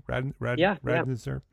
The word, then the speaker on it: Radziner: He began his career with the renowned design build firm Marmol Radziner. Is that pronounced properly? – Radziner. Yeah.